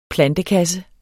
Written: plantekasse

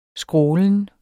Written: skrålen

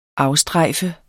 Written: afstrejfe

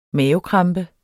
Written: mavekrampe

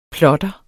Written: plotter

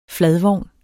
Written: fladvogn

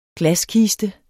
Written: glaskiste

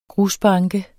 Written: grusbanke

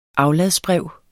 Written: afladsbrev

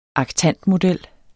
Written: aktantmodel

